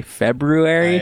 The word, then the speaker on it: February: It is in the word, but it's kind of like a silent E or like February. Do you say February? – I actually do kind of make the R shape with my mouth.